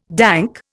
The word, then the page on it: dank: Indian